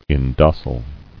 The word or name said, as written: Indocility